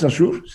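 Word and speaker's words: Saussure: Sasur, ¿cómo se pronuncia? Sasur, Sasur. Eso sí.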